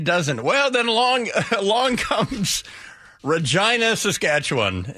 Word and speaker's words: Regina: It looks like it's spelled Regina, but it is pronounced Regina.